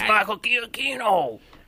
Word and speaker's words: Giacchino: It's Michael Giacchino. – So I didn't say it like that.